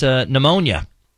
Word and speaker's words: pneumonia: How about pneumonia? – Ooh, all right. That starts with a P. – P-H? – P is silent.